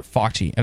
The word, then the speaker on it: Fauci: Dr. Fauci.